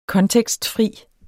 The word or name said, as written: kontekstfri